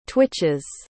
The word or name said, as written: twitches